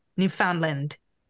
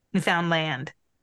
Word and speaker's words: Newfoundland: Note our Canadian province of Newfoundland is pronounced so it rhymes with understand. Newfoundland. Newfoundland.